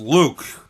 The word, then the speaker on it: Luque: Spanish name, but it looks like a French last name. And everything I've learned about French last names is that you don't pronounce most of the letters. Yeah. L-U-Q-U-E. So Luke.